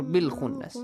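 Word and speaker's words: بالخنس: How do you say it? Bikhunnas or bilkhunnas? – Bilkhunnas.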